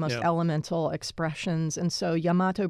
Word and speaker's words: Yamato: This Yamato, is that how that's pronounced? – Yes, Yamato. So when I was at IMG, I had the great privilege to work with the great Japanese taiko group Kodo.